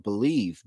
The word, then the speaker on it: BLEAV: Use our promo code BLEAV, B-L-E-A-V, BLEAV, but spell it B-L-E-A-V, and you can receive your 50% welcome bonus on your first deposit.